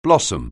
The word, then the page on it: blossom: anglais